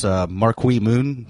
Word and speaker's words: Marquee Moon: We're talking about, of course, Marquee Moon. That's how you say it, right? – It is.